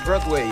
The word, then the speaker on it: Broadway: Bueno, estás muy bien de pronunciación, porque en realidad sí es Broadway, así es.